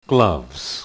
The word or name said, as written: gloves